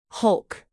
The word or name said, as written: hulk